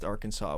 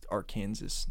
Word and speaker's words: Arkansas: I just don't like the way that it's pronounced Arkansas when it's Arkansas.